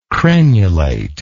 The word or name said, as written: crenulate